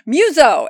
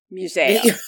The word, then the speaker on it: Museo: Yes, because it's not Museo, it's Museo. Ha ha. Which I said it the first time. I was like, Muso. And Mark's like, Museo. And I'm like, but it's spelled Muso. M-U-S-E-O. Muso. And he's like, but in, they say the letters.